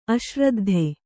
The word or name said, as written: ಅಶ್ರದ್ಧೆ